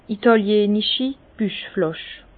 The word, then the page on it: itàliänichi Buchflàsch: Bas-Rhin; Herrlisheim